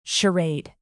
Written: charade

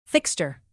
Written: fixture

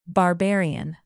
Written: barbarian

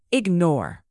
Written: ignore

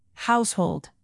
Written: household

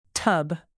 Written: tub

